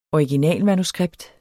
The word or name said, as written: originalmanuskript